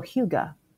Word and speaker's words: hygge: So hygge, I didn't know how to pronounce it until I looked it up for you guys. It's that word you sometimes see on Pinterest, H-Y-G-G-E.